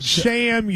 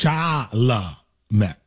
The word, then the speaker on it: Chalamet: You never once managed in that segment to say it correctly. You said sham, you said charm, you said...